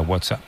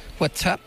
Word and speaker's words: WhatsApp: WhatsApp. – No, peor aún, con acento en la primera. WhatsApp, ¿no?